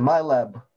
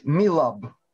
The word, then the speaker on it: MILAB: Если читать ее в транскрипции «My Lab», а правильно говорить «MILAB» – «Military Abduction» – «Военное похищение».